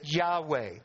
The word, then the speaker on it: Yahweh: Y-H-W-H, we sometimes pronounce it Yahweh.